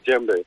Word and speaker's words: djembe: It's called a djembe. Is that right? – That's that's perfect. Yes, it's a djembe.